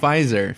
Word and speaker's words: Pfizer: How do you say it? Pfizer? – Pfizer. – Pfizer. – Yeah, Pfizer.